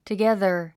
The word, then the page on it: together: təgéðə r; トゥギャザァ